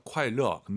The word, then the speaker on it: 快乐: 快乐 안 돼요. 르어예요. 快乐.